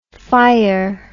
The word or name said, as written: phyre